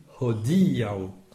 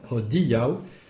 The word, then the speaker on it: hodiaŭ: Alors, attention aussi à l'accent tonique. Hodiaŭ. – Hodiaŭ. – Voilà, il faut accentuer le di. Hodiaŭ.